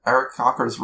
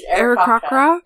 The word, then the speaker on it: Aarakocra: Sorry, I don't know how to say it. – It's all hard K's. – Aarakocra? – Aarakocra?